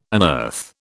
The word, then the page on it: unearth: British English